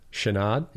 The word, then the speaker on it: Chenard: How do you say his name? – Yvonne. – Yeah. And last name? – Chenard. – Yeah, I was going to say Chenard, but I was like, it sounds too easy.